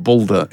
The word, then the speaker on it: Baldur: No, he's going to be the god Balder in God of War, which Balder. – Is a... – Balder, how do you pronounce that? – B-A-L-D-U-R. – D-U-R, okay.